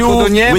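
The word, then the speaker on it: Codognè: Si scrive Codogné, ma... – With you, gne. – No, si scrive Codogné, ma si legge con una gne.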